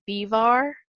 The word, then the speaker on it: Biewer: It's spelled B-I-E-W-E-R, but it's German, so I think the way you say it is Bivar.